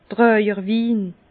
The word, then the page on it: Tràjerwin: Bas Rhin